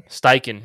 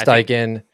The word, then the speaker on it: Steichen: Steichen? – Steichen, yeah. – Steichen.